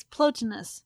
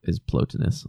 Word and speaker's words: Plotinus: You're mispronouncing it. It's Plotinus. – It probably for Americans is Plotinus. I was trying to do the Latin.